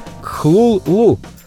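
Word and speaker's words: Cthulhu: А у группы «Металлика» есть песня 1984 года под названием «Зов Ктулху» хотя на самом деле имя самого известного мифического существа Лавкрафта надо правильно произносить примерно как «хлул-лу». Об этом рассказал сам автор мифов.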